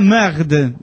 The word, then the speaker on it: merde: Bueno, y dice, solo díganle a la merde, Se pronuncia merde.